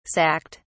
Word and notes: sacked: British English

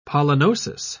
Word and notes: pollenosis: British English

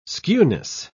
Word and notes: skewness: British English

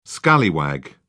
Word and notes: scallywag: British English

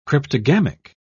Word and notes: cryptogamic: British English

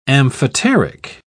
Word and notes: amphoteric: British English

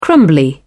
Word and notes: crumbly: British English

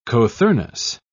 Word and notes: cothurnus: British English

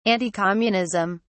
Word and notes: anticommunism: British English

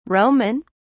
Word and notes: Roman: British English

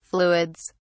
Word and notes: fluids: British English

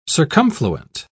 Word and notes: circumfluent: British English

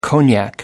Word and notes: cognac: American English

